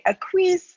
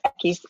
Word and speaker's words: Equis: Thank you, Tara, and thank you for saying the name Equis right, because 9 times out of 10 people say Equis, or somehow butchered in another way, but Equis is the right way to say it, just like the letter X in Spanish.